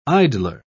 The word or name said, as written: idler